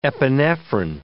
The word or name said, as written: epinephrine